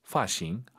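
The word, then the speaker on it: 发型: 어, 꽤 많은 분들께서 파싱 이렇게 발음해요, 선생님. F 발음을 좀 어려워하세요. 파싱, 파싱 아니고 파싱. 도파 아니고 더파. 정확히 F 발음 소리 많이 나야 됩니다.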